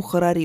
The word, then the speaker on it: Харари: Именно поэтому я не смогла проникнуться, например, книгами Харари – или Харари, по-моему, Харари правильное ударение, его книгами и книгами прочих людей, которых принято называть публичными интеллектуалами.